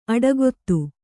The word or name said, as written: ಅಡಕೊತ್ತು